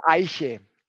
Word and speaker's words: Eiche: The Aisha? Tell me how I should pronounce it. – Echt Schlenkerla Eiche. Eiche is the German word for oak tree.